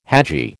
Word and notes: hadji: American English

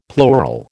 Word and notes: plural: American English

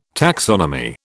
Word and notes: taxonomy: British English

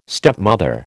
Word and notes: stepmother: American English

